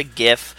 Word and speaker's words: GIF: It's definitely gif. We've been over this. The founders call it gif. If you want to be an asshole and call it a gif like an idiot, that's fine.